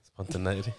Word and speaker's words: spontaneity: Spontaneity. – That's how you say it.